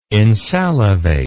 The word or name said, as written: insalivate